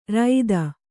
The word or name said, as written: ರೈತ